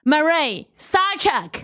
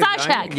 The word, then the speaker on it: Murray Sawchuk: Are you ready for the one and the only, the very amazing Murray Sawchuk? I said it right. – Yes, you did. – Woo, Sawchuk.